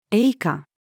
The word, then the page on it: 詠歌: female